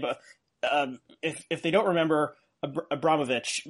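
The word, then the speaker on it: Abramović: Abramowitz. Forgive me if I'm saying that wrong. You can pronounce it correctly. – Abramowitz, yeah.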